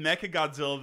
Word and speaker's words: Mechagodzilla: And then we've got fucking Mechagodzilla, the secret arriving contender.